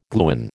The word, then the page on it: gluon: American English